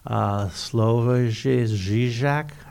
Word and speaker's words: Žižek: We're reading, you know, we've long been friends of Slavoj Žižek.